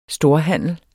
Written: storhandel

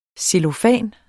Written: cellofan

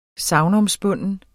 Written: SAGNOMSPUNDNE

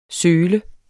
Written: sølet

